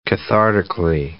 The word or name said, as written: cathartically